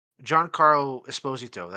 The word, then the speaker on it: Giancarlo Esposito: This is something we kind of talked about, where I think we can both agree, and all three of us, Giancarlo Esposito, that's how it's actually pronounced, I found out this week, he's had the best career since Breaking Bad.